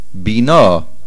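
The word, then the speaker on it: bina (בינה): Also, be careful to say bina. Umlamed le'enosh bina. The emphasis on the nun.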